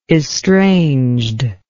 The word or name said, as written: estrangedness